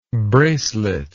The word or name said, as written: braceleted